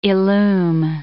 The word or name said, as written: illume